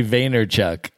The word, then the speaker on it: Vaynerchuk: Gary Vaynerchuk.